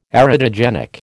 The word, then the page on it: erotogenic: American English